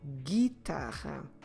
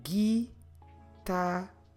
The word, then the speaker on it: guitarra: For instance, when we say guitar, guitarra, guitarra, guitarra. We're not pronouncing the U, just like we're not pronouncing the U in English either. Guitarra. Como se escreve guitarra? G-U-I-T-A-R-R-A.